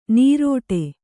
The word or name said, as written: ನೀರೊಡೆ